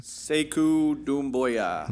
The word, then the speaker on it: Sekou Doumbouya: Sekou Doumbouya.